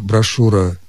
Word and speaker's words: брошюра: Произносится здесь, конечно, твердо, согласно, «ш» мы можем произнести только твердо. И поэтому хочется написать после этой буквы «у». В словах «брошюра», «жюри» и «парашют» мы, тем не менее, пишем букву «ю».